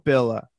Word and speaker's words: Villa: I don't think it's Villa. It'd be double L in Spanish is like a Y sound.